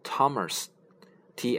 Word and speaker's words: Thomas: 比如说，我们有一个人名，嗯，是 Thomas，t h o m a s，Thomas，托马斯，这里面的那 h 呢就是不发音的。还有一个类似的名字是 Thompson，t Thompson 啊，那这里它是拼写是 T H O M P S O N，不是 Thompson，而是 Thompson 啊。